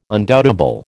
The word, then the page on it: undoubtable: American English